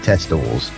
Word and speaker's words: Testors: Right, this is a band, 1976-77, Prime Primitive, CBGBs, Max's Kansas City's regulars, Testors, but it's T-E-S-T-O-R-S, so Testors. I'm sure you would pronounce it Testors, but I don't know whether that's how the Americans spell Tester or not.